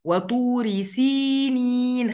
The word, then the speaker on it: وطور سينين: وطور سينين، وطو، و، أرقق الواو، و و و، طو طو طو، أضم شفايفي كويس وأنا بنطق الطاء، طو، ما أقولش وطوري، طو، لا هي مش طو، هي طو، طو، وطوري، أكسر الراء كويس، وطور سينين.